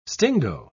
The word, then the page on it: stingo: British English